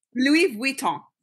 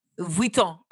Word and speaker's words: Vuitton: Louis Vuitton. – Vuitton. Okay, so you know we're all like Louis Vuitton.